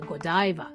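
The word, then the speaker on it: Godiva: I ではなくて I になってゴダイバっていうふうに発音しちゃうんですね。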